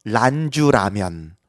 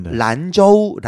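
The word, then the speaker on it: 란저우 (兰州): 그냥 한국어 발음 먼저 말씀드릴게요. 네. 란주라면. – 란주라면. – 원어로는요. 란저우 라면입니다.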